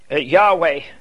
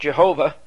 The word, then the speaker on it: Yahweh: Yahweh, often mispronounced Jehovah.